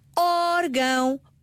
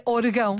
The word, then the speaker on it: órgão: Em órgão, acento no O. Orégãos, acento no E. Porquê? Porque se eu não tiver acento, naturalmente as palavras terminadas em ão gostam de receber a sílaba tónica. Irmão. Coração. – Neste caso seria orgão. – Pois, não, se eu não colocar o acento sobre a vogal, a tonicidade recai no ão.